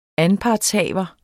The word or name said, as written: anpartshaver